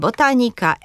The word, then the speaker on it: botanika: Drugą sytuacją, kiedy akcentujemy trzecią sylabę, są rzeczowniki łacińskie lub neołacińskie zakończone na ika i yka. Mówimy zatem botanika, etyka, galaktyka, pedagogika czy polityka.